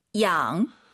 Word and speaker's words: 养: Y가 있으면은요, 마음속으로 I로 바꾸세요. 이게 한글로 많은 분들이 에 양, 양 이렇게 읽으시는데 정확한 발음은 이 양이에요. 그죠? – 네, 양. – 네, 이로 시작해야 돼요. 양이 아니에요.